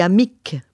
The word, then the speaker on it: amic: Par exemple, les consonnes finales se prononcent. On dit amic pour dire l'ami. On dit prat pour dire le pré.